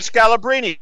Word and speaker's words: Scalabrine: Brian Scalabrine.